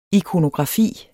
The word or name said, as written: IKONOGRAFI